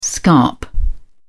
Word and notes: scarp: British English

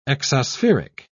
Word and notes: exospheric: British English

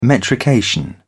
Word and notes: metrication: British English

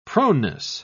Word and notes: proneness: British English

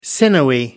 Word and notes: sinewy: American English

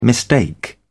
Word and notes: mistake: British English

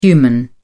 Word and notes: human: British English

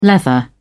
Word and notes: leather: British English

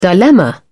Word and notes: dilemma: British English

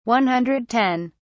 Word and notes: 110: British English

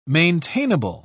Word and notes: maintainable: British English

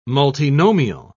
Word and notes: multinomial: British English

